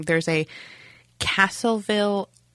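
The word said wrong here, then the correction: Castroville: artichoke festival? – I think he said Castorville.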